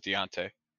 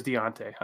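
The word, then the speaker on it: D'Onta: Yeah, I looked up pronunciation and it was Deontay. – It is Deontay.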